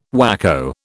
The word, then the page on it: whacko: British English